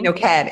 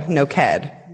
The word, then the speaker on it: Noked: It's Noked. It's like naked with an O. Noked. – Naked with an O. – The emphasis here is on the end of the word. It's like the last syllables.